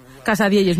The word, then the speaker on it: Casadielles: Y nos trajo una cosa que se llama Casadielles, ¿no? ¿Lo digo bien? – Casadielles, muy bien dicho.